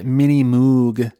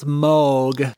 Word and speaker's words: Moog: And he does, in this clip, say Mini Moog. – Yeah, I heard that. Is the incorrect pronunciation. Technically, it's Moog, but whatever.